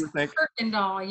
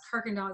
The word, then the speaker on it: Kuykendall: You would think, but it's Kuykendall. You pretend like the Y's and R's. Kuykendall is Dutch German.